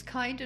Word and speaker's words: Kind: So how do you pronounce it there? – Kind. – Kind. – Like kindergarten.